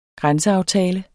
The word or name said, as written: grænseaftale